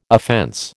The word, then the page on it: offense: American English